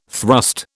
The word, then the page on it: thrust: British English